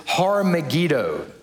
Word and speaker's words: Har Megiddo: The Hebrew word is Har Megiddo. That's literally how that word would be pronounced in Hebrew.